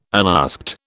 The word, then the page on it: unasked: British English